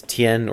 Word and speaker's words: Tian: The character for sky in Chinese, Tian, or in Japanese, Ten, and the character for earth, which is in Chinese, is Di, or in Japanese, Qi.